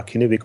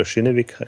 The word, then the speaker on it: Kinnevik: Aber offenbar gibt es jetzt die beiden Kapitalgeber, also ich nenne es mal wieder mit K, Kinnevik oder Schinnevik, glaube ich, heißt es äh, richtig ausgesprochen,